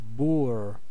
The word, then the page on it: Bauer: [ˈbaʊ̯ɐ]; /ˈbaʊ.ɐ/